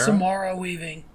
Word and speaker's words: Samara Weaving: Samara Weaving.